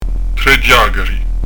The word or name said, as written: ghjacari